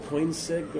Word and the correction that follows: Koenigsegg: Koenigsegg? – Yeah, Koenigsegg.